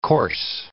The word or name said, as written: coarse